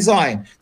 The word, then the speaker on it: Zion: It's pronounced in English, they say Zion.